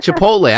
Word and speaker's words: Chipotle: I can never say it right. – Chipotle. I think I'm saying it right. Am I saying it right, Dad? – Yes, you are.